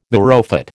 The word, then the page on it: marrowfat: American English